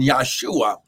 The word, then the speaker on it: Yahshua: He was given an earthly name, Yahshua, because it would not have been Jesus, because there is no J in the Hebrew language, so it would have been pronounced and spoken Yahshua.